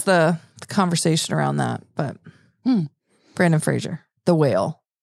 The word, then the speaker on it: Brendan Fraser: Didn't you see Brennan Frazier? – Where would I see Brennan Frazier? I don't even know what that is. – Brendan Fraser, apparently we've been saying his name wrong all these years, but he's in the new movie The Whale, and he wore what is called a fat suit.